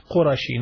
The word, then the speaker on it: Qurashi: Yeah, it should be pronounced Qurashi, not Qurashi. Qurashi means someone from the Quraysh tribe.